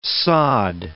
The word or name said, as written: sod